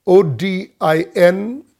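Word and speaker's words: ōdin: And the term sorrows there is the word Odin. O D I N, it's pronounced Odin, and it means birth pangs.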